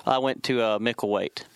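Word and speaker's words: Micklethwait: It's called Micklewaite. And so it's spelled Micklewaite, and the TH is silent.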